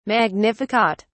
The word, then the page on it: magnificat: British English